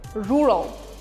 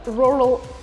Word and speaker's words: rural: Rural. Сельский. Слово произносится не как rural, а rural.